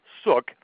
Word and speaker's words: Suk: I have interviewed a Dr. William Suk. It's William S-U-K. It's pronounced like look. William Suk.